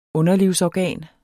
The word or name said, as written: underlivsorgan